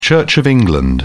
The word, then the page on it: church of england: British English